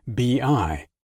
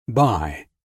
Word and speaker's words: BI: Business intelligence is commonly known simply as BI. That's pronounced B-I, not by.